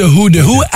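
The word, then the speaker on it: Hu: Non, pas de Who, de Who. H-U, c'est comme ça que ça s'écrit.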